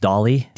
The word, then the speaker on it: DALL-E: I think it's pronounced DALL-E, D-A-L-L-E.